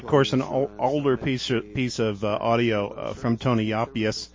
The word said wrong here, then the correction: Yapias: Yapias. Sorry, I want to be very correct in that pronunciation. All the other people seem to be at great pains to make sure and pronounce his name right.